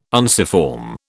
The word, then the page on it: unciform: British English